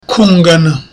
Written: kungana

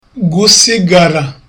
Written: gusigara

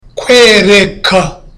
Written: kwereka

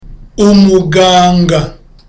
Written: umuganga

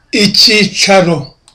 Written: icyicaro

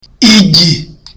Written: igi

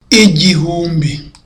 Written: igihumbi

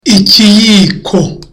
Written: ikiyiko

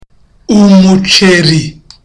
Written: umuceri

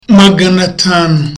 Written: magana atanu

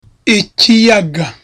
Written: ikiyaga

